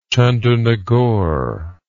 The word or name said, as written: chandernagor